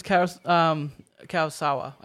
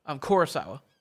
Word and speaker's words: Kurosawa: It's funny because Kurosawa, I keep saying his name wrong, Kurosawa.